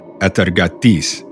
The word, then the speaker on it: Atargatis: Let me pronounce that right. – Okay, yeah. – Atargatis. Hey, how does that sound? Hey, not too bad, right? – That's perfect, man.